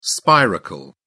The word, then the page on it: spiracle: British English